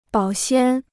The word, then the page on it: 保鲜: bǎo xiān